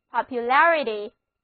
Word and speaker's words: popularity: 现在我们在 popular 后面加上 ity 的名词后缀，那么单词 popularity 注意重音后移了，元音字母 a 发作 s 的梅花音，单词就读作 popularity，意思就是普及性，流行程度。